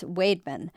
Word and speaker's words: Weidman: As the Morning Sun of Alma reported, a community in Isabella County that looks on paper like Weidman is actually pronounced Wademan.